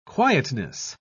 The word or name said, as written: quietness